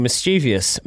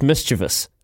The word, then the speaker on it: mischievous: I know that Foster said that Jamie Joseph was being mischievous. My God, Ian Foster is literate. He can't even pronounce the word properly. It's mischievous.